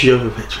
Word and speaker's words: Jovovich: Jovovich, thank you.